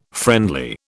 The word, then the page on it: friendly: British English